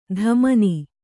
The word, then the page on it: ಧಮನಿ: dhamani; Kannada